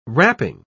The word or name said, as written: wrapping